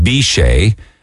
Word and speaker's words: Biche: And then talking about towns with crazy names, if I mentioned yesterday morning that Facebook took off a town in France, they probably pronounce it Biche, but on paper it looks like the word bitch.